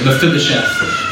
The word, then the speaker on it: Mephibosheth: He finds Mephibosheth. That's how you say it, Mephibosheth, the son of Jonathan, who is crippled in his feet.